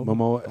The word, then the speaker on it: Momoa: Momoa.